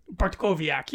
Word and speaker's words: Bartkowiak: Bartkowiak? – Bartkowiak, yes. That's it.